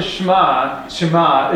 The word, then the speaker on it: Shema: S-H-E-M-A.